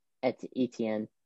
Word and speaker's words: Etienne: what, how do you say his name? Etienne? – Etienne. It's like weird.